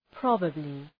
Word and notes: probably: 'prɒbəblı

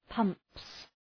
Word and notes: pumps: {pʌmps}

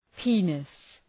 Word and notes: penis: ‘pi:nıs

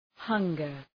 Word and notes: hunger: ‘hʌŋgər

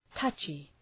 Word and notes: touchy: ‘tʌtʃı

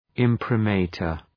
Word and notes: imprimatur: ,ımprı’mɑ:tər

